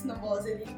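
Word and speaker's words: Novoselić: E fu Chad Channing, che è il batterista dei Nirvana, dall'88 al 90, a introdurre Kurt Cobain e Chris Novoselic alla musica di Bowie. – Come hai detto bene, Chris Novoselic. – Ho fatto inglese. – Hai fatto serbo.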